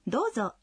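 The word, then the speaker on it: «Дозо» (どうぞ): «Дозо» означает «пожалуйста». Когда произносите «дозо», в первом слоге гласное «о» долгое.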